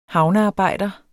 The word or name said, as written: havnearbejder